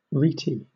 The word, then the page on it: rete: British English